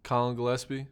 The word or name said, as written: Collin Gillespie